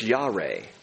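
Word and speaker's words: Yare (ירא): It rhymes with Yahweh. It's Yare.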